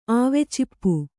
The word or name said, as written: ಆವೆಚಿಪ್ಪು